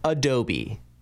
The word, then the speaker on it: Adobe: It's Adobe.